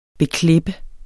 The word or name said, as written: beklippe